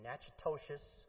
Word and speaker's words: Natchitoches: Joe Delaney was a two-time All-American athlete in football and track who played at Northwestern State University in Natchitoches, Louisiana.